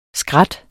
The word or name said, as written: skrat